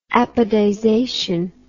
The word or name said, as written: apodization